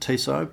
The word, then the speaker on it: Tissot: Yeah, so however you want to pronounce it, I pronounce it Tissot, but it was a Tissot PRC 200, which is...